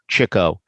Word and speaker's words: Chico: If you listen to the original cast album, you'll hear Chico, but these people pronounce it as Chico. But that's really the correct way, Chico, because the concept was way back then that he used to like to chase chicks, as they were called in those days.